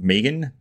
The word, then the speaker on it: M3GAN: I watched the 2022 horror thriller movie called Megan, but that's spelled with a 3, so M3GAN.